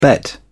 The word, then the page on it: bet: British English